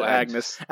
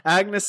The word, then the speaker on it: Agnes: Like all Simpsons specials, it had many laugh-out-loud moments, particularly like the gags with Angus? Agnes? – Angus? – It's spelled Agnes. Agnes Skinner, Goldilocks, and the dolphin fight scene, which I loved.